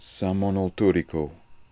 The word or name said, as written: Samonoltooriko